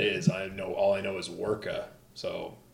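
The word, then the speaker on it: Warka: I think in Polish, W's are already is V. – They're pronounced as a V, so I feel like we're already off to the wrong foot here because we should be saying Worka.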